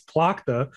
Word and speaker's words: Plakta: I just want to point out for anybody who gets mad at Noisy Pixel pronunciation, I looked up the katakana for Plakta so that I could say it right. – Oh. – I looked up Purakuta, so I was like, okay, it's Plakta.